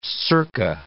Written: circa

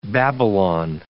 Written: Babylon

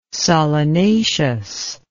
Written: solanaceous

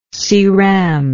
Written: ceram

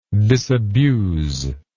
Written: disabusal

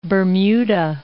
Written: Bermuda

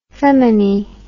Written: feminie

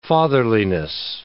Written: fatherliness

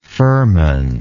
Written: firman

